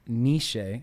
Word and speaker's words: Nietzsche: You know, the German philosopher Friedrich Nietzsche, I think I pronounced his name right?